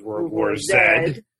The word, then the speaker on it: World War Z: So World War Z. – Good one, okay. – Proper pronunciation is World War Zed.